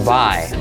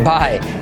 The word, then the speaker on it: by: Yes, hoy tenemos otro vídeo retante, challenging, desafiante, que es los usos de la preposición by, que no se dice by, sino by, ¿vale? Como I, by, y no son ni más ni menos que 24.